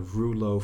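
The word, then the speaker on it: rouleaux: And so this formation is called a rouleau formation. R-O-U. – Is it roulox? Is it rouleau or roulox? – I'm going with the French pronunciation.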